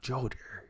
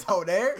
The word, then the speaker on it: Joder: For your Joder. To eat her. – You know what? And I would correct you and say it's Joder.